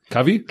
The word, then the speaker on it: Covey: so he said, Milo said, to quote Stephen Covey, I think that's how you say his name. – Covey.